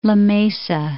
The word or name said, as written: La Mesa